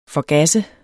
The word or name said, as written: forgasse